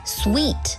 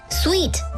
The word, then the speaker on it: suite: 如果是多人旅行，或者是全家出游，你还可以考虑订上一间套房 suite，注意发音是 suite，S-U-I-T-E， suite， suite 通常包含卧室和客厅，豪华的 suite 还会有小厨房哦。